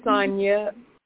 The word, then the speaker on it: Sonya: My name is Sonia, and I would just like a general reading. – Sonia, how do you spell that? – S-O-N-Y-A.